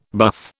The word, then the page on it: buff: British English